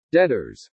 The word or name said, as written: DEBTORS